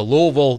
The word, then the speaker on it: Louisville: Josh on our text line says, as a Louisville native and hater, say it like LOL, but quickly, Loserville is also accepted.